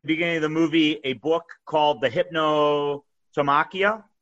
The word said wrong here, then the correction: Hypnerotomachia: Hypnerotomachia, which, do you know what that's about?